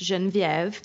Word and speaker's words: Geneviève: It's like Genevieve, but it's pronounced Geneviève.